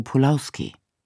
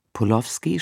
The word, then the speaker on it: Polowski: Wie die meisten hier Interviewten verwenden wir die deutsche Aussprache. Polowski statt Polowski, Kotzebue statt Kotzebue.